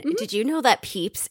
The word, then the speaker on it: Pepys: apparently it's pronounced Peeps. – Okay. – But it's written P-E-P-Y-S. It looks like Peppis.